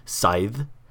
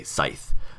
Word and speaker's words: scythe: It's a silent C, yeah. So it's scythe or scythe. So it's either like the TH in the, then, there, or that, or the TH like in thing, thumb, or bath.